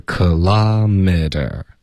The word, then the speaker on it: kilometer: 嗯，一般美语的发音是 kilometer， OK， kilometer。